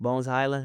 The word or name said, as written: bones HYLAND